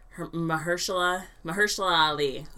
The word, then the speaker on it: Mahershala Ali: It's Mahershala Ali,